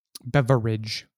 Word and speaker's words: beverage: Beverage. Three syllables, people.